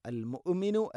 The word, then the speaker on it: المؤمن: അൽ ഖുദ്ദൂസുസ് സലാം, السلام المؤمن السلام المؤمن المؤمن المؤمن المهيمن المؤمن مؤمن ألا المؤمن ഹംസയുടെ ഉച്ചാരണം കൃത്യമാക്കണം, തൊണ്ടയുടെ താഴെ ഭാഗമാണ്. എന്നാൽ ഖൽഖല വരാൻ പാടില്ല. അൽ മുഅ്മിനു അല്ല, അൽ മുഅ്മിനു, മുഅ്മിനു എന്ന് ഹംസ തീരെ ഉച്ചരിക്കാതിരിക്കാനും പാടില്ല.